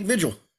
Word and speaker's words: Vigil: It's pronounced Vigil. No, he said it's fine to call him Nick Vigil.